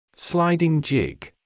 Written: sliding jig

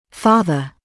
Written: farther

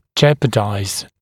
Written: jeopardize

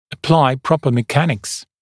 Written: apply proper mechanics

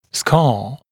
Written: scar

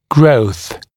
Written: growth

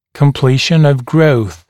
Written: completion of growth

